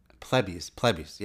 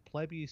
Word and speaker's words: Plebis: When I made the podcast, I was trying to figure out how to pronounce Plebis. – Plebis. Yeah, I got to keep remembering that. That's how I say it. – Okay. – But you can find about 40 different pronunciations, but Plebis seems to be probably about 70% of the pronunciations I found were that. So I went with it.